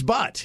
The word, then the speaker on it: Butt: It is Butt. It's B-U-T-T. It's right there. His name is Butt. I'm not mispronouncing it. It's not like a silent U in the middle. It's just B-U-T-T.